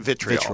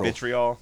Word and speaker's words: vitriol: There's not nearly as much, was it virtual? How do you say that word? – Vitriol. – Vitriol. Yeah. Virtual, what an idiot.